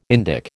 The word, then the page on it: Indic: American English